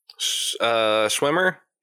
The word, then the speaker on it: Schwimmer: And David Schwimmer, am I saying his name right? – Schwimmer. – Yeah, David Schwimmer.